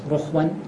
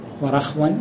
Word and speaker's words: رخو: قال وارتداد وارتياده لبوله مكانا رخوا، طبعاً رخوا قالوا مثلثة الراء، مثلثة الراء ايش معنى مثلثة؟ أحسنت، يعني أنها تنطق الراء بثلاث حركات رخوا ورخوا ورخوا فهي مثلثة الراء.